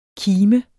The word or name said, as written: kime